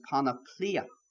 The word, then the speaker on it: panoplia: The actual word that Paul uses there for whole armor is a Greek word pronounced panoplia. The Anglified version of that's almost like a panoply.